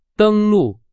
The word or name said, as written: 登录